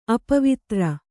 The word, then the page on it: ಅಪವಿತ್ರ: Kannada; apavitra